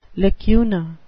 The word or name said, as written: lacuna